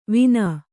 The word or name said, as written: ವಿನಾ